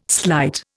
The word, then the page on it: slight: Indian